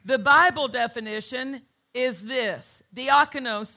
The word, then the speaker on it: diakonos: It's spelled D-I-A-K-A-N-O-S. Diakonos. D-I-A-K-A-N-O-S. Diakonos. Sounds like the word deacon.